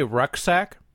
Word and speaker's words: rucksack: That's yeah, you said it weird. – Okay. – You de-emphasize the second syllable so much it rucksack. – Sounded like a different word.